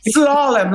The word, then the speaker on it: Slalom: Slalom, like the skis. – Oh, Slalom. Right.